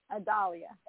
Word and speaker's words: Adalyah: What's your Israelite Adalia. – Name? – Adalia. – Adalia. Adalia. Spell it for me. – A-D-A-L-Y-A-H.